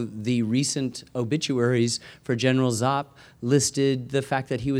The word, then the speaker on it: Giap: G-I-A-P, pronounced Zapp.